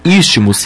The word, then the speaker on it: Isthmus: Istmus se escreve ISTHMUS.